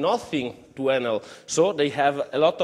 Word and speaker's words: Enel: Enel is a I think in Italian we pronunciate it Enel.